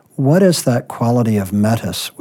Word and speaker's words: metis: And I may not be pronouncing it right, but the word you use is metis. Am I pronouncing it correctly? – Yes, metis. Yes, metis is fine, yes.